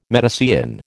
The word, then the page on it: Medicean: American English